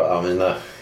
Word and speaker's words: Amineh: Okej, okay, men berätta då Amineh. Kabave, är det som man uttalar namnet? – Amine, ja. – Amine, och efternamnet? – Kakabave. – Kakabave. Precis.